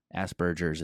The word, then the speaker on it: Asperger's: Asperger's, it's pronounced.